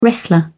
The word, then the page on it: wrestler: British English